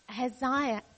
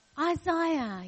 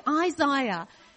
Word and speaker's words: Isaiah: This is Isaiah. I said it wrong. Isaiah. He taught me. He told me yesterday. He said, remember, I, Isaiah.